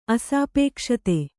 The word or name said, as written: ಅಸಾಪೇಕ್ಷತೆ